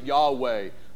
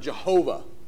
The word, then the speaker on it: Yahweh: The Hebrew word there is the consonants YHWH. We pronounce it Yahweh. You also have the corruption, the English corruption of Jehovah.